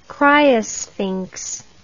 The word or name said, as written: criosphinx